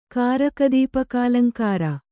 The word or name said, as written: ಕಾರಕದೀಪಕಾಲಂಕಾರ